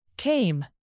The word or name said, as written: came